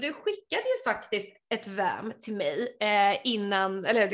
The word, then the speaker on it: Vaam: för du är ju grundare och CTO på VAM, eller eh, visst uttalade jag det rätt? VAM? – Det var helt rätt, helt rätt.